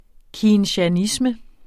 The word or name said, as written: keynesianisme